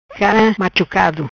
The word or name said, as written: Cara machucado